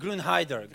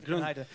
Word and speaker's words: Grünheide: Um, ähm, nicht, na, Grünheide. No, Grünheide. Grünheide.